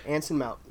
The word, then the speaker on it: Anson Mount: Anson Mount.